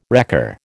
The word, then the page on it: wrecker: American English